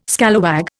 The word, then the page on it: scalawag: Indian